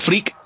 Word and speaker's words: FRIAC: que se escribe FRIAC, como Freak, FreakCon, lo pones ahí y a disfrutar y a dejarte llevar por todo el contenido.